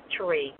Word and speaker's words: Terrie: It is Terrie. It sounds like Marie, but with a T.